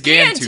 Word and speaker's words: Gantu: Gantu? – Gunta. I pronounced it wrong, but I did write it down correctly. – Okay. No, it's Gantu. His name it is Gantu.